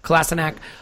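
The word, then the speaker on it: Kolašinac: And by the way, yes, we have changed that pronunciation because, as I understand it, that's how he wants to be called. So Kolasinac, taking him out,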